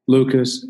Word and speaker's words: Lucas: and I'll spell that for you. So it's J-A-Y Lucas, L-U-C-A-S dot U-S, J-Lucas dot U-S.